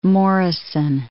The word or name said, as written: Mount Morrison